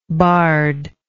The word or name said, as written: bardship